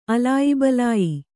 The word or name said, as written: ಅಲಾಯಿಬಲಾಯಿ